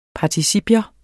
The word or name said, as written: participium